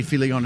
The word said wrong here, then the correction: Filion: It's Filon. I'm putting an extra syllable in there for you.